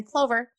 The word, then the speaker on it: Clover: And Christy is spelled K-R-I-S-T-I. So Christy with a K and an I. And then Clover, just like four-leaf clover.